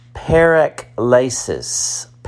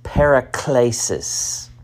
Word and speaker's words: paraklesis: The Bible word for encouragement is pronounced paraklesis, paraklesis.